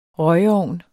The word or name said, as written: røgeovn